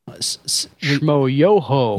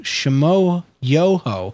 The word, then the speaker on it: Schmoyoho: One of the things that me and John really like the most right now is how do you say the name of their channel? – Shmo Yoho. – Yeah, it's spelled Schmoyoho. Shmo Yoho.